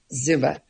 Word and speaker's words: Zumba: Zumba. – it rhymes with Roomba.